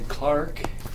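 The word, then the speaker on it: Clarke: Clark, and Clark is spelled with an E on the end.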